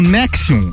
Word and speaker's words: NXIVM: NXIVM, pronunciado como Nexium, liderada por Kate Ranieri e pela própria Alison Mack.